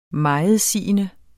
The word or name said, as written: megetsigende